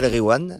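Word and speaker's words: Nass El Ghiwane: Donc la chanson de Nas El Gwiam, je vais mal prononcer. – Nas El Gwiam. Voilà. Et quand il y a GH, tu dis re. Tu fais comme si c'était un R.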